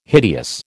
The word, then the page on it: hideous: American English